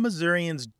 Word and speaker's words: Missouri: Because some Missourians don't say Missouri or Missouri-a, they say Missouri, more like that E in get or met.